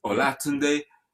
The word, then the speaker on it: Olatunde: Olatunde.